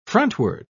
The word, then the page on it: frontward: British English